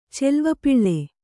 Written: ಚೆಲ್ವಪಿಳ್ಳೆ

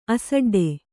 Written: ಅಸಡ್ಡೆ

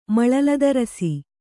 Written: ಮಳಲದರಸಿ

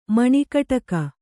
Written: ಮಣಿಗಡಗ

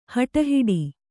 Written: ಹಟಹಿಡಿ

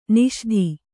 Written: ನಿಷೀಧಿ

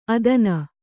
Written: ಅಧೀನೆ

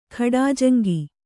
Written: ಖಾಡಾಜಂಗಿ

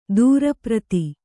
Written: ದೂರಪ್ರತಿ

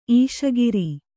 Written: ಈಶಗಿರಿ

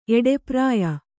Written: ಎಡೆಪ್ರಾಯ

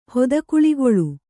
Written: ಹೊದಕುಳಿಗೊಳ್

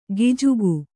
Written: ಗಿಜುಗು

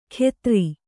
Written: ಖೆತ್ರಿ

